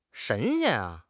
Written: 神仙

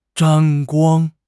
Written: 沾光

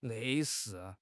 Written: 类似

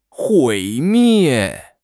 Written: 毁灭

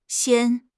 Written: xian1

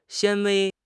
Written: 纤维

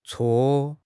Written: cuo4

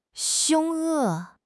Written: 凶恶

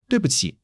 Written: dui4 bu5 qi3

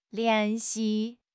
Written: lian2 xi4

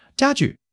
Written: jia1 ju4

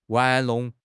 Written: 玩弄

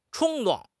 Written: 冲动

